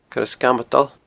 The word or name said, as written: Coire Sgamadail